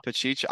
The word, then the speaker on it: Pacheco: Pacheco. I heard Pacheco on Fantasy Pros. They pronounce it Pacheco.